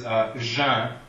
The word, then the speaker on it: geint: Another good one is jeun. G-E-I-N-T, it's one sound, but it has five letters in it. The T is silent. The N only nasalizes the two vowels that are before it. – Well, and the E actually only serves to soften the G, too.